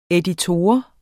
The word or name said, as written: EDITOREN